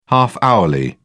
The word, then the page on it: half hourly: British English